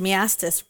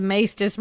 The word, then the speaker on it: Maestas: I also just realized I just now pronounced it wrong. It's the Maetas murders.